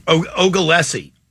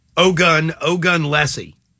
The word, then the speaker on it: Ogunlesi: Ogunlesi. Ogunlesi. – How do you spell that? – O-G-U-N-L-E-S-I.